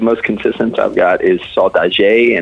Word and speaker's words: Saudade: It's, I believe, okay, this is a problem because it's a Portuguese word. It's Saudade, I believe, or Saudade.